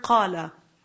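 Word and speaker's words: qala (قال): So for example, the very common word qala, what is it actually? Qawala. Qawala. Because what's the root? Qaf. Waw lam. So technically it should be qawala.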